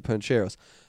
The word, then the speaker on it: Pancheros: Disclosure, I've never gone to Pancheros. Pancheros is pronounced... pan, not pawn, so pancheros, and it refers as panchos guys like Pancho Villa or guys that have Pancho's back.